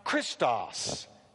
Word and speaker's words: Christos: The word Christ is pronounced Christos in the Greek language, and it means anointed or anointed one.